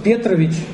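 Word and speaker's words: Petrovich: Ну, зовут его Дуглас Петрович. Я буду так ставить ударение, чтобы он не был у нас Петровичем.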